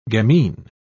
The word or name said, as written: gamine